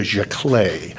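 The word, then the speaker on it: giclée: And then from there, I think the next level in terms of quality and rarity goes what is called a jaclé. And it's a weird word. It's a French word. It's spelled G-I-C-L-E-E-S, or jaclés, or jaclé as a singular.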